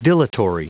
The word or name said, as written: dilatory